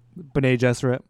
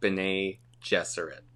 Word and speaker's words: Bene Gesserit: How would you pronounce their name? – Bene Gesserit. – I'm here to say that I'm going to officially change my pronunciation. – Wow. That is crazy. – I have decided I'm going to try and start saying Bene Gesserit.